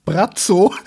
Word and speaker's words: Braco: Tatsächlich, Croatian Pronunciation, Brazzo.